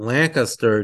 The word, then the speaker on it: Lancaster: Well, the first thing I guess is to remind you that Lancaster does not rhyme with disaster. It's Lancaster, not Lancaster, as the natives make.